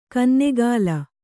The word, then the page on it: ಕನ್ನೆಗಾಲ: kannegāla